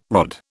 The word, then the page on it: rod: British English